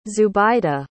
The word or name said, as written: zubaida